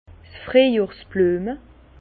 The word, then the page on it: 's Friejjohrs-Blüeme: Bas Rhin; Herrlisheim